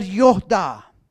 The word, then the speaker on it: yada: Now this word, I know, this Hebrew word know, is the word yoda. It's spelled Y-A-D-A.